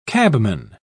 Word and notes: cabman: British English

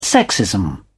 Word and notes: sexism: British English

